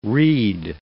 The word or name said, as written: reed